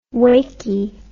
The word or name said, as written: wakey